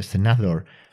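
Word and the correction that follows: Szandor: LaVey. – Sandor.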